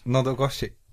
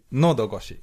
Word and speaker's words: Nodogoshi: Hur, hur uttalar man det här namnet? – Nodogoshi. – Madogoshi. – Nodogoshi. – Nodogoshi.